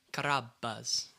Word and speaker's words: Carrabba's: The B is doubled as well, so you have to also when consonants are doubled in Italian, they're meant to be longer. So it's like Carrabba's.